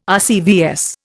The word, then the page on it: RCVS: Indian